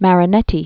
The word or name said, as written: Marinetti